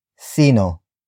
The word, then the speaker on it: sino: The first word is sino, which is spelled as S-I-N-O.